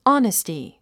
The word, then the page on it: honesty: άnisti; オネスティ